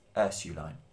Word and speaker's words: Ursuline: And as soon as I said yes, I realised what he was actually asking me, which was he wanted me to go, no, it's Ursuline. – Yeah. – And to pronounce it like that.